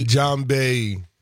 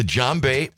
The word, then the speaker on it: djembe: The djembe. – The djembe.